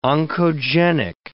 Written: oncogenic

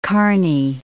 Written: Kearny

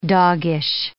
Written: doggish

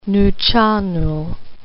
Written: Nuu-Chah-Nulth

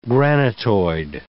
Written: granitoid

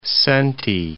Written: senti

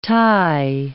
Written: Tai

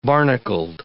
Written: barnacled

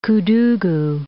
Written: Koudougou

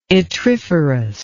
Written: yttriferous

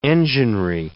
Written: enginery

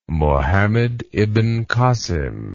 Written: Mohammed ibn Kasim

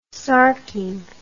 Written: sarking

